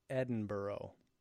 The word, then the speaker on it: Edinburgh: It's Banshee that wonders that, and it's pronounced Edinburgh. – Oh. – Did you know that? – No.